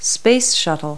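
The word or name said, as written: space shuttle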